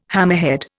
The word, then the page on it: hammerhead: Indian